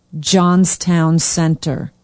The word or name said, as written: johnstown center